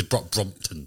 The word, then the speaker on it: Brompton: I love that you said to look at it, just make sure you pronounce it correctly. Like, it's Brompton.